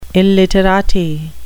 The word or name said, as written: illiterati